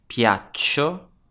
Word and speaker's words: piaccio: Spelt P-I-A-C-C-I-O. This verb is a little irregular in the spelling because the C doubles up in the I form. So piaccio with two C's. You can also hear it in the pronunciation. The C is a little longer.